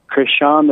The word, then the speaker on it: Crishaun: New Mexico, you're live with Crishaun the Don. – Good morning, and good morning, Crishaun the Don.